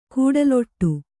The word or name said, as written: ಕಡಲಾಡಿ